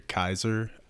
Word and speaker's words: Caesar: So, my idea, Brian, I think in the Latin it was actually pronounced closer to Kaiser.